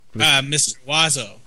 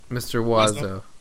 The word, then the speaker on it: Mr. Oizo: Mr. Oizo. – Mr. Oizo.